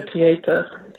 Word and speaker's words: Pietà: She's the one and I remember when I went to Italy and I did go to the Vatican and I saw and I always pronounce it wrong, the Pietà, did I pronounce that right? – Yeah, Pietà. – Pietà, yeah, I always get that wrong.